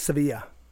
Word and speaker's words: Sevilla: In Spain, it's Sevilla.